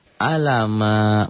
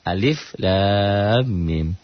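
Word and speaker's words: Alif lam mim (الم): Alama. Bukan. Alif lam mim.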